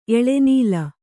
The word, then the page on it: ಎಳೆನೂಲು: Kannada